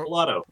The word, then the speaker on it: Pilato: Joseph Pilato? – Joe Pilato.